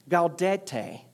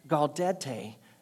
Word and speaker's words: Gaudete: And so they determined that the third Sunday of Advent would be known as, let me, I made sure I put it out phonetically to, so I could say it correctly Gaudete Sunday. The third Sunday of Advent would be called Gaudete Sunday.